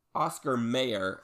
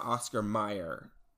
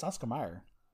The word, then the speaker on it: Oscar Mayer: Is it's Oscar Mayer and not Oscar Mayer. – It's Oscar Mayer. – But it's spelled M-A-Y-E-R and not M-E-Y-E-R.